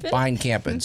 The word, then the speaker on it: Beinkampen: Beinkampen.